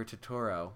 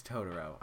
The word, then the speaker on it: Totoro: I guess I'm just going to be doing a few Studio Ghibli movies I've seen, like My Neighbor Totoro, which I saw, like, last week, and... – Isn't it Totoro? – Yes, it's Totoro. I'm sorry. – You pronounced it wrong.